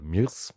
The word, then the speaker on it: mIRC: Also wenn ihr das mal austesten möchtet, IRC, speziell MIRC, also oder MIRS, höre ich auch immer recht häufig als Aussprache.